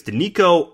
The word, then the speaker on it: Denico: You got Denicio, I hope I pronounced that correctly. I think it's Denico Autry.